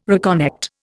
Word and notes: reconnect: Indian

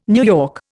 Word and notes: NY: Indian

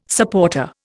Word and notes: supporter: Indian

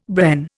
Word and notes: Bren: Indian

